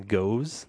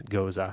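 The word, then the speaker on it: Gose: When I first saw these types of beers, I kept calling them goes. – Or goose. – Or goose. But apparently it's that the German pronunciation is Goza.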